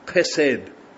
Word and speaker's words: chesed: So the word is this word, chesed, okay, so with a guttural H.